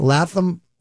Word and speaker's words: Lapham: That's Latham Farm Village, but it's spelt L-A-P-H-A-M.